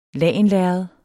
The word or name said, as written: lagenlærred